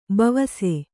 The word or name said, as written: ಬವಸು